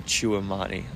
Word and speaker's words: Tchouaméni: Apparently, we're getting Tchouaméni.